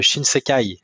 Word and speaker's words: Shinsekai: le Shinsekai, si je prononce bien.